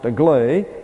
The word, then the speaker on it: agley: Aglay, A-G-L-E-Y, pronounced aglay, is a Scot adverb meaning astray.